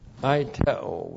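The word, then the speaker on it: aiteo (αἰτέω): Aiteo, which looks like this in the Greek, A-I-T-E long O, aiteo.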